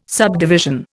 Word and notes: subdivision: Indian

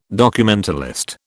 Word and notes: documentalist: British English

